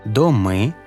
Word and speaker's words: доми: Насамперед відмітимо, що багато іменників вимовляються тепер із наголошеним закінченням «доми», «дуби», «цехи».